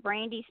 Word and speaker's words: Brandie: And if you need to get a hold of me, just look up Brandy Spencer. My first name is spelled B-R-A-N-D-I-E.